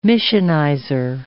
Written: missionizer

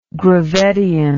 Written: gravettian

